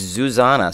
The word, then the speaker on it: Zuzanna: Speaking of Susanna, and I want to say it right. It's Susanna with a Z. It's Susanna.